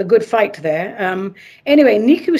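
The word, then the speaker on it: Nicu: And this is from, I think it's pronounced Niku. it's N I C U.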